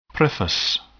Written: preface

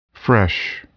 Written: fresh

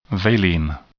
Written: valine

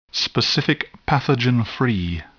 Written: specific pathogen-free

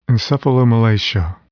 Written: encephalomalacia

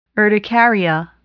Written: urticaria

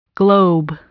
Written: globe

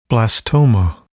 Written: blastoma

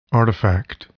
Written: artifact

